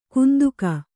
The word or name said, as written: ಕಂತೆಗ